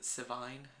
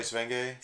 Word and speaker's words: Sevigny: Is it Sivigne? Is that how you say her? – Chloe Sevigny.